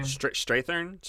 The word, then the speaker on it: Strathairn: Strathairn.